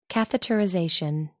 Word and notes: catheterization: American English

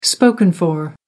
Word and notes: spoken for: American English

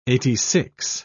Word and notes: eighty-six: British English

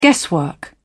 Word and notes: guesswork: British English

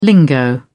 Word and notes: lingo: British English

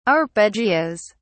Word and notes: arpeggios: British English

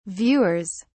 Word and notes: viewers: British English

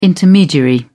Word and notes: intermediary: British English

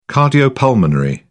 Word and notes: cardiopulmonary: British English

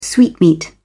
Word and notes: sweetmeat: British English

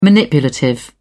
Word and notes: manipulative: British English

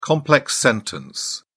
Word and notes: complex sentence: British English

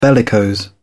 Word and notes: bellicose: British English

